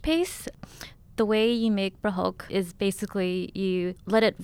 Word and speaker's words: prahok: And so let's talk about prahok. Mm-hmm. Am I saying that right? – Oh, yeah. Prahok. Perfect.